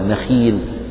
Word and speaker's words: نخيل: هذا إذا كسرت القاف نحو مستقيم مستقيم من نخيل نخيل، يعني هذه الخاء المكسورة تنطق هكذا نخيل، لا تقل الخاء من حروف التفخيم فتقول نخيل مثلا، لا، لأن الخاء مكسورة فهي في أدنى درجات التفخيم كأنها مرققة.